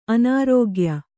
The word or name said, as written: ಅನರ್ಘ್ಯ